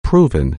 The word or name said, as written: proven